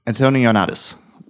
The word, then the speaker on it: Antoniades: So basically, what it is is the director and writer of the game, Tameem, Tameem Antoniades.